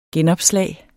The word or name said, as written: genopslag